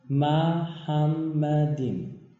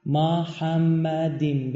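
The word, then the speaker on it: محمدیم (מחמדים): اما اینجا یه کلمه وجود داره که عبریش تلفظش اینه، ببینید، محمدیم.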